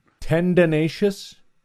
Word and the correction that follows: tendentious: How do you say that word? – I don't know what word you're saying. Tendinacious? – T-E-N-D-E-N-T-I-O-U-S. – Tendentious? – That's it.